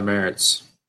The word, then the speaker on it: Marz: Uh, you can follow me on Twitter at Tyler Marz 61, and you can follow me on Instagram at just Tyler Marz.